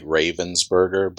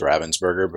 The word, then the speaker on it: Ravensburger: It's spelled like Ravensburger, but it's Ravensburger because they're a German company.